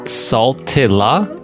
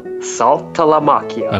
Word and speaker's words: Saltalamacchia: So, Jared Saltalamacchia. – Saltilla? – Saltalamacchia.